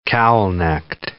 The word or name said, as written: cowl-necked